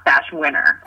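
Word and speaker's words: Bashwiner: I'm going to tell you that my last name is pronounced Bashwinner.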